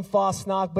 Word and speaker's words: Fosnock: But we do pronounce our name Fosnock. But if you want to get real Dutchy, then get Pastor Chris, and he can teach you how to do it that way.